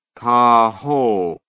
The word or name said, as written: cahot